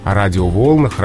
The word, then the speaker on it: радиоволнах: Как ни странно, здесь уже, здесь тоже допустимы варианты, но здесь уже предпочтительно радиоволнам, радиоволнах, радиоволнами. Угу. Вот так вот. То есть появляется вот этот компонент, он как оттягивает ударение с, с конца слова. Ну, видимо, чтобы не было столько предударных слогов. То есть без радио по волнам, на волнах как только появляется радио, радио волнами, а радио волнах.